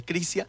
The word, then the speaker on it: Crizia: Pero al mismo tiempo yo les puedo decir, bueno, su restaurante, Crisia, Yo tiendo a decirle Crizia, pero es Crizia, porque se escribe con Z.